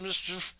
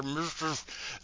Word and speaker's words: MSCHF: Nike not only disavowed any connection with the shoes, they sued Mischief, M-S-C-H-F, which I assume is pronounced Mischief. There's no vowels. Just run all that shit together. Mischief.